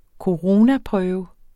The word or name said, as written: coronaprøve